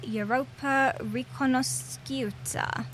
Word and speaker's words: Europa Riconosciuta: Opened in Milan with a performance of Salieri's opera Europa Riconosciuta. – Oh, very nice. – Lovely. – Nicely pronounced.